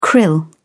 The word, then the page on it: krill: British English